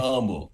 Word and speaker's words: Humble: And as much as I love to talk about Humble, because I'm very proud to be from Humble, Texas, it's pronounced Humble. – It is pronounced Humble.